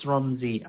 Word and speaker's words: Romzi: Rifaat, R-I-F-I-I-T. And then the last name is Romzi, R-O-M-Z-I.